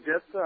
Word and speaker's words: Jephthah: My question today is going through the book of Judges again, and Judges 11 concerning Jephthah.